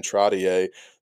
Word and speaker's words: Trottier: Which tied for the most points in a period since New York Islanders Brian Trottier did it way back in 1978.